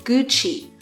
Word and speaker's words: Gucci: 啊，古奇读作 Gucci，